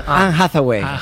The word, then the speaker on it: Anne Hathaway: Anne Hathaway. – Eva, ¿está bien dicho? – Está perfectamente, Perfecto.